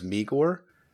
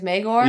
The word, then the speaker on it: Maegor: I think what threw me off is that he kept pronouncing Maegor as Meegor. Like throughout the entire like he's meager. That does not a good connotation for someone as strong as Magor.